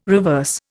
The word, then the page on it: reverse: Indian